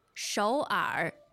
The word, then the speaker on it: 首尔: 首尔。首尔, 首尔. What are the tones there? – Two-third tones.